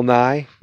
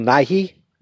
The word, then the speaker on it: Nighy: It was Bill Nye. No, Bill Nighy. Not Bill Nye, the science guy. I think it's Bill Nighy is how you pronounce the actor's name.